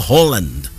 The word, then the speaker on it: Haaland: What a brilliant, brilliant performance from Erling Haaland. By the way, it's a Holland, apparently. That's how you're supposed to pronounce it.